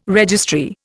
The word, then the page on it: registry: Indian